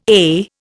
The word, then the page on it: eh: Indian